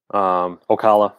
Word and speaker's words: Ocala: Ocala, that's how they say it down there.